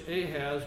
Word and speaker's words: Ahaz: It says, when Ahaz, we would pronounce it in English Ahaz, but really the word is Achaz. The H is soft in English, but it's really a CH sound, which is the Kahet.